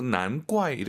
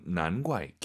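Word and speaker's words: 难怪: 근데 쌍기역으로 난과이 이렇게 발음하는 중국인도 있죠. 근데 우리 왕 선생님은 난과이 기역 느낌으로 발음을 했다는 걸 참고하시면 좋을 것 같습니다.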